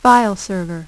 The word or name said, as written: file server